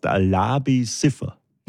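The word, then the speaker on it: Labi Siffre: Das ist Labi Siffreil. – Ich glaube, so heißt er nicht. – Nein, er heißt Labi Siffer. – Na, siehst du. Das ist richtig ausgesprochen.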